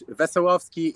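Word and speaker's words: Wesołowski: Wesolowski, you know, in Polish, Wesolowski, in English, I don't know. Maybe Wesolowski is okay because you you don't know a Polish word like we and the other words.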